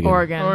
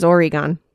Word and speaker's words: Oregon: How do you say Oregon? You say it normal, right? – Oregon. – Because everyone back east says Oregon.